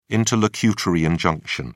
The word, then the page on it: interlocutory injunction: British English